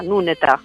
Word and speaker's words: Nuneta: En réalité, on le prononcerait comme ça, Nuneta. Mais du coup, voilà, en français, après, ça sonnait Chet Nuneta. On a donné un accent un peu différent.